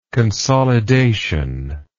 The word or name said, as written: consolidative